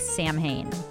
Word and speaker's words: Samhain: And if you look at this word, it's spelled S-A-M-H-A-I-N. It looks like Samhain, but it is Celtic, Gaelic, so it's pronounced Samhain.